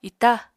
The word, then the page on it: ita: apatani